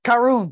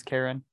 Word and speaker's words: Karun: I don't know why I remember that, but it's K-A-R-U-N on the subtitles. – There you go. – But I was saying it wrong in my head. I thought it was Karen.